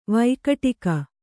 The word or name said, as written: ವೈಕಟಿಕ